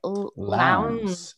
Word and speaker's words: Lowndes: And Dr. Jeffries, I'm not sure how to pronounce Bloody Lowndes. Lowndes. Yeah, okay. Lowndes. Okay, mm-hmm, good.